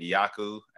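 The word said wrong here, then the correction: Aiyuk: Brandon Ayuk? – Ayuk, yeah, last year.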